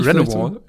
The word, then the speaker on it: Renewal: ist die Renew Wall. – Ja, ja. – Die Leute auch, gerne falsch aussprechen. – Meinst also Renewal.